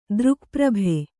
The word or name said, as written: ದೃಕ್ಪ್ರಭೆ